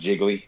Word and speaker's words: Gigli: So if I may go over a few of those, Bad Boys Two, um, Gigli, Freaky Friday. – oh my God. – Johnny English. Do what? – pronounced Jiggly.